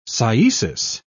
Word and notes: cyesis: English; British English

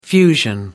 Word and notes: fusion: American English

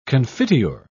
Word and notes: confiteor: British English

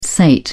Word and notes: sate: British English

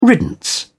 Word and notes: riddance: British English

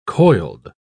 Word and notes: coiled: British English

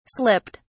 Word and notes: slipped: British English

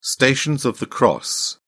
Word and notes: stations of the cross: British English

